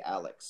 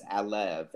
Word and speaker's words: Alev: I want to say Alex so bad. But it's Alev, A-L-E-V, Aiden.